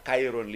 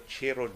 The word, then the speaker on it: Chiron: Ang Bugatti Chiron, mao na siyang Americanized nga pronunciation. Ang kung gusto juga mag-French o gusto ka mag-Italian, ang ilang isulti, Bugatti, Bugatti uh, Chiron ang, ang argon sa Spain imo mag ipakara ta imo tutulan. so dili dili ka mong yung Chiron sa sa French. Kung gusto gyud ka mag French, Chiron.